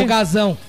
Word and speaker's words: folgazão: Folgação. – Que? Folgazão. – Folgazão, é isso. – Peraí, tá com Z ou com C cedilha? – Tá com Z, eu falei errado.